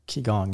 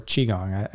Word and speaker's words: Qigong: through Qigong and practicing it, or Qigong. Is there two different ways to pronounce it? Or it doesn't matter.